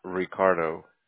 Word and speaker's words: Ricardo: Ricardo. – Can you spell that for me, please? – That's R-I-C-A-R-D-O.